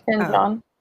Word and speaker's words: Ken Jeong: Ken Jeong.